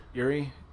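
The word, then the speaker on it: Urie: Dude, Brandon Erlein, or how you pronounce his last name? Yuri.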